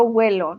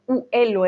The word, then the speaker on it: huelo: o sea, yo huelo, no digo yo uelo, eh, uelo, uele, no, suena raro, ¿no? Si pronunciamos mucho la u al principio, es más como si fuera una u, uh, más o menos, yo huelo, tú hueles, él huele, es casi como una g ahí escondida, ¿vale?